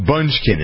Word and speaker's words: Bündchen: There's no K. – Bündchen. – Bündchen. – Bündchen. – She's not a munchkin, she's a Bündchen. – But CH could be pronounced K, Miss Know-It-All. Bündchen. – No one pronounces it Bündchen.